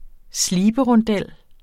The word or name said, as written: sliberondel